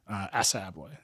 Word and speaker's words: Assa Abloy: A year ago, you decided to sell to the big company, Assa Abloy.